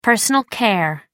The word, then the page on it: personal care: American English